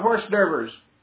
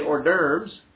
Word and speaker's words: hors d'oeuvres: And they're going to have a lot of horse d'oeuvres. For those of you that grew up where I did, that's how you pronounce it here. You say hors d'oeuvres.